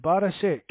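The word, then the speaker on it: Barišić: Is it Barisic? You pronounce it Barisic? – Yeah.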